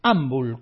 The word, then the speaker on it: Ambul: se llama Ambul, Ambul, con acento en la A.